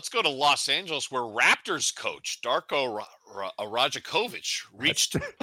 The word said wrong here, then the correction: Rajakovic: Anyway, Darko Rajakovic reacted Tuesday night.